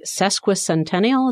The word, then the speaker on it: sesquicentennial: To celebrate the sesquicentennial. Is that how you say it? Sesquicentennial? – It is.